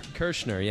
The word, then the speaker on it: Kershner: Kershner, yeah.